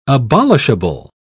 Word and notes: abolishable: British English

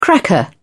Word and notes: cracker: British English

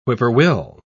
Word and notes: whippoorwill: British English

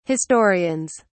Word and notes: historians: British English

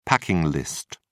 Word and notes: packing list: British English

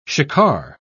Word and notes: shikar: British English

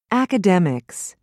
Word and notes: academics: British English

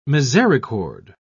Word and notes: misericorde: British English

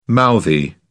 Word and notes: mouthy: British English

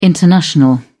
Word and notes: international: British English

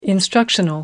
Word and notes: instructional: British English

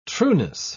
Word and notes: trueness: British English

